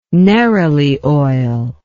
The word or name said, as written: neroli oil